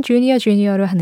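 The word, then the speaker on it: JR JR: 어, 발음은 주니어 주니어로 하는데 표기는 JR JR로 합니다.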